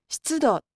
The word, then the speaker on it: 湿度: この漢字は何と読みますか？ – 湿度と読みます。